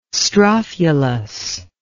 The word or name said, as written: strophulus